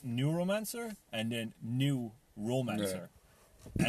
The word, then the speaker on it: Neuromancer: Neuromancer is a song on it, like I said. He does this great thing. See the way it's spelled N-E-U-R-O, neuro as in neurological.